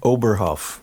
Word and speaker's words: Oberhoff: Oberhoff.